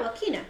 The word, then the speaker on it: Joaquina: Yeah, Joaquina.